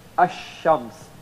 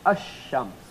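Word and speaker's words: ash-shams (الشمس): then you don't pronounce the lam, you cannot pronounce the lam. You have to connect the hamza directly to the first alphabet, and then you put a small shad on top of the first alphabet. So you would say ash-shams, as if the letter is like of course it's not written like this, but I'm writing it so that in order for you to understand it easily, so you will pronounce it like this, ash-shams.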